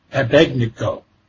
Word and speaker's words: Abednego: Abednego.